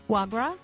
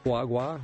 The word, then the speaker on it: foie gras: Did I say that right? – Foie gras? – Foie gras, yeah.